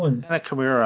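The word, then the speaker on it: Hana Kimura: and then the news about Hannah Morkey, or my pronunciation is always the worst, but a young Japanese professional wrestler at the age of 22, from all indication, had taken her life due to a myriad of reasons stemming from a reality show. – Hannah Kimura, I believe, is the pronunciation.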